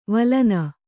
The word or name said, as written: ವಳನ